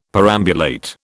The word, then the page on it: perambulate: British English